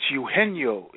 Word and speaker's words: Eugenio: Oh, it's Eugenio. It looks like Eugenio, like E-U-G-E-N.